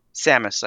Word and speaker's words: Samus: Samus, I think.